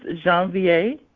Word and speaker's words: Janvier: It's Janvier.